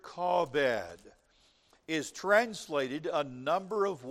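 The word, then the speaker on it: kabod (כבוד): If we were to pronounce it in Hebrew, it would be something close to the word kabod. We would say it that way.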